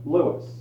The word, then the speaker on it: Louis: In religion, his name was Lewis. L-O-U-I-S, but always pronounced Lewis, although he was at times called Louis, and sometimes Uncle Louis, by the novices.